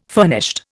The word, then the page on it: furnished: Indian